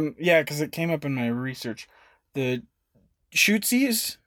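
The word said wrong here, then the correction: Tutsi: Well, how do you say it? Tootsie. Tootsies. Yes. Yeah.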